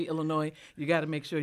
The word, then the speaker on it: Illinois: Would you tell Darren it's pronounced Illinois instead of Illinois? And he spelled it with an E.